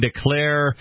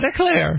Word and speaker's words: de Cleyre: And there's a short essay by Voltairine de Cleyre titled Patriotism, A Menace to Liberty. – By the way, you pronounced that one correctly. – One of the things de Cleyre.